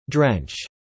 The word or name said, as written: drench